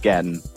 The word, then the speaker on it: Gatian: Yep, it's Gatian.